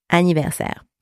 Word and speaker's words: anniversaire: So the letter S, different ways to pronounce it in French, but in that particular case, it is pronounced S, S. Same as the S from snake, S. And the letters A, I are pronounced also A. A. Then we have our R, and then we have our final E, and the final E here is silent. You do not pronounce it. So we have ser, ser. All right, so that gives us a ni ver ser, anniversaire.